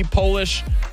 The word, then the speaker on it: Polish: There is one word in the English language that changes its meaning and pronunciation whenever you capitalize it. What is that? Polish. Capital P, Polish, lowercase p, Polish. Changes everything.